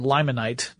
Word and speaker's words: limonite: limonite, or limonite, depending on how you want to pronounce it, sometimes called bog iron, and magnetite, which is also known as lodestone, among others.